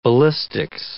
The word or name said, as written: ballistics